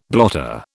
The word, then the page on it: blotter: British English